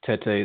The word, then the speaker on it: Tete: Tete is how I always say it.